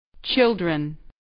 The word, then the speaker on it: children: Children. Chil. Dren. Children. – جمع این کلمه کاملا بی‌قاعده است. به تلفظ دقیق children توجه فرمایید. بگویید بچه ها.